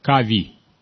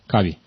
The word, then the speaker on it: Covey: Стивен Кови. Стивен Кови. Стивен Кови. Третье. Кави. По-английски пишется Covey. C-O-V-E-Y. Кави.